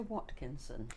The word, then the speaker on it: Watkinson: We are interviewing Brenda Watkinson. – And can you spell that for me, please, Brenda? – Yes, B-R-E-N-D-A W-A-T-K-I-N-S-O-N.